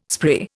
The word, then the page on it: spree: Indian